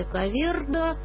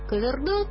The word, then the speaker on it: Коверда: Кстати, некоторые мигранты настаивают на произношении Коверда. Ну, тут я не знаю, поскольку я впервые познакомилась с этим именем из стихов, где звучит именно как Коверда, то я оставляю за собой произношение Коверда.